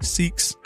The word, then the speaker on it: Sikhs: Sikhs? – Sikhs, yeah.